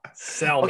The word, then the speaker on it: salmon: salmon. Okay, wait. Okay, say it. Go ahead, say it. Salmon. – Salmon. – Salmon. Salmon. That's what I said. Salmon. – You definitely pronounced the L. Earlier.